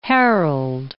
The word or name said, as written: Harold